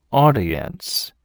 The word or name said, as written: audience